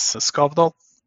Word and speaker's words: Skavdal: That is Skavdal.